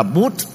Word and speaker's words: Buddha: Ví dụ như Buddha thì người Ấn Độ đọc là Bút.